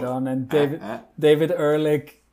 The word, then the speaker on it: Ehrlich: Everyone seemed relatively positive, except second favorite Carl Boy after David Sims, David Elric. He was lukewarm. Did I say his name right? Not David. – David Ehrlich, yeah.